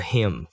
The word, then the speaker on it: hymn: This is a hymn for how we live. Hymn 就是圣诗哦，H Y M N。嗯，但它的发音跟就是第三人称男生的那个他的受格 H I M him 是同一个发音，但就是要注意一下前后文，不要搞错这两个字了。